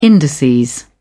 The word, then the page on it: indices: British English